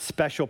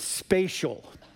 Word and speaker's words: spatial: It's not special, pronounced wrong. It's spatial.